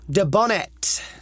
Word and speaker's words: Dubonnet: Dubonnet. – Dubonnet. – It's spelled French. D-U-B-O... – Double N. – Double N-E-T.